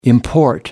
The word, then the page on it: import: American English